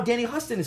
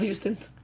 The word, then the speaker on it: Huston: Danny Huston. – Not Houston? – No, it's Huston.